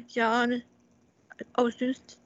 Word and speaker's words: John Ozuna: and he's the actor John Ozuna.